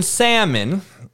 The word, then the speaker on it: salmon: The L is silent, English major motherfuckers. So I just want to make sure you know the L in salmon is silent. So you should say it like salmon, like S-A-M-M-O-N.